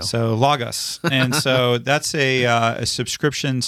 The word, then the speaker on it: Logos: It's Logos, and the reason is Logos, this is too much information, is because what are transliterated as O's are omicrons. – Yes. – Not omegas. If they were omegas, it would be pronounced logos, but they're omicron, so it's logos.